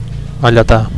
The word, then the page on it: alletar: Catalan